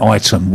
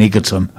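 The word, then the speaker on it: Ightham: Normally have a laugh with Jules with pronouncing the names of places in Kent, like Item, which she insists on calling Egerton.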